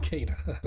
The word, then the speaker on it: Kena: Kena, I'm tired of people pronouncing it wrong. Anyway... it's freaking Kena.